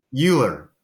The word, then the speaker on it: Uhlir: Uhlir.